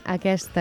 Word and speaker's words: aquest: No cal pronunciar-la si darrere d'aquest hi ha una consonant, però davant d'una vocal sí, com ara examen, aquest...